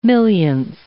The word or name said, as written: millionth